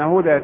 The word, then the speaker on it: هدى: أيضا هناك كلمات نجد الألف في آخرها كتبت ألفا وبعضها يكتب ياء، مثال هدى وعصى، نجد أن هدى تكتب ياء مع أنها تنطق ألف، والتي نسميها الألف اللينة، وفي كلمة عصا تكتب ألف ولا تكتب ياء.